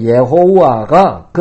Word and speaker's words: 여호와: 그것이 곧 우리말로 여호와라는 발음이 되었는데 아마도 예호와가 그들이 붙이는 모음으로는 더 가까운 발음인지도 모르겠습니다.